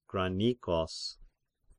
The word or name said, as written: Granikos